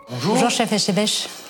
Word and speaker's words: Etchebest: Etchebest. The... – Voilà. – Bonjour Etchebest. – Nous sommes donc avec le chef Philippe Etchebest. – Et ben voilà, notre public le prononce bien.